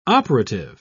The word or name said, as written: operative